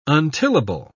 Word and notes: untillable: British English

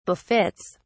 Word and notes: befits: British English

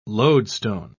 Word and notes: loadstone: British English

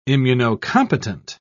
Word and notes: immunocompetent: British English

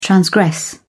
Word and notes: transgress: British English

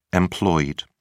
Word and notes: employed: British English

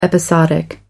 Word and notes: episodic: American English

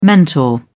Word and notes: mentor: British English

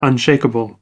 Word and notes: unshakeable: American English

